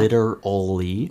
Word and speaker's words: literally: 이거 L L R L L 세 개에다가 R 하나. – 네. – 좀 어렵죠. – 엄청 복잡합니다. – literally.